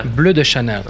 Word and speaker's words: Bleu de Chanel: Bleu Chanel? – Bleu de Chanel. That's the proper pronunciation.